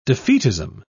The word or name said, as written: defeatism